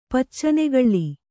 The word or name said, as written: ಪಚ್ಚನೆಗಳ್ಳಿ